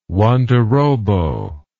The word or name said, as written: Wandorobo